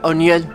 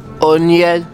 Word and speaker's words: O'Neill: O'Neill. – O'Neill. – Yeah, but a lot of people has pronouncing the Spanish N. – Really? – So I just let them say O'Neill.